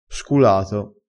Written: sculato

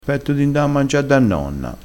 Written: ha furia

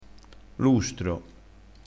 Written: lustro

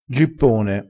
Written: gippone

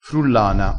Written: frullana

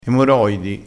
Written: emoroidi

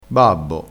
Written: babbo

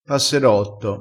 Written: passerotto

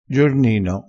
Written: giornino

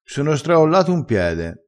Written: straolla

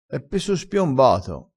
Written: spiombato

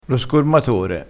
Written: scormatore

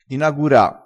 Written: inagura

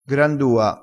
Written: grandua

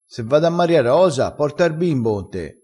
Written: strascina

